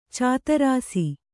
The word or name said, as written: ಚದುರಿಸು